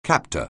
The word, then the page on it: captor: anglais